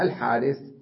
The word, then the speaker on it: الحارث: قال رابعا الحارث، الحارث يكتبونها الحرث ويلفظونها الحارث، أيضا الألف بعد الحاء تلفظ ولا تكتب، هكذا.